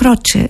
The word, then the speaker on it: Croce: Αλλά σαν σήμερα το 1973 ο Jim Croce, έτσι προφέρεται το όνομά του, ήταν στο νούμερο 1 στην Αμερική με αυτό εδώ το τραγούδι Bad Bad Leroy Brown.